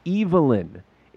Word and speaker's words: Evelyn: The first name is pronounced with a long E, as in Eve. Evelyn. It is generally pronounced like this when it's a man's name.